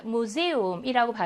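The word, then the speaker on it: Museum: 복모음이 활용이 된게 아니라 각각의 모음으로 본다. 그래서 에 하고 우로 발음합니다. 무세움이라고 발음하거든요.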